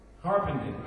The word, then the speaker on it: Harpenden: Harpenden. How do you pronounce this town? Where are we? Harpenden. All right. It's great to be here in Harpenden.